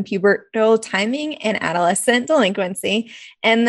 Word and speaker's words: pubertal: I was like, it looks like puberty, so I'm just going to go with pubertal. – Yeah. – No, that's how I say it.